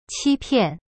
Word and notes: 欺骗: qi pian

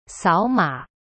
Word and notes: 扫码: sao ma

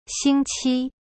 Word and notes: 星期: xing qi

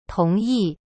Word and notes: 同意: tong yi

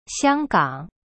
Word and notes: 香港: xiang gang

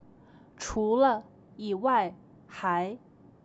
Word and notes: 除了…以外，还…: chu le. yi wai. hai

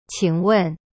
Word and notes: 请问: qing wen

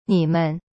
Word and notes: 你们: nimen

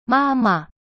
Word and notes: 妈妈: ma ma

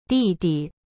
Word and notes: 弟弟: di-di

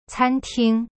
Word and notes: 餐厅: can ting

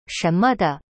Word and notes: 什么的: shen me de

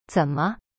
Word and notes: 怎么: zen me

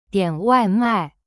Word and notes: 点外卖: dian wai mai

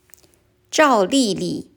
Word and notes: 赵莉莉: zhao li li

